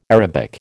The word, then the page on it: Arabic: American English